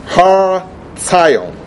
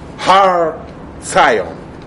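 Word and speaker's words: Har Zion (הר ציון): Har Zion. That's how the Hebrew pronounce Mount Zion. Har Zion.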